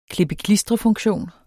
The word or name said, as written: klippe-klistre-funktion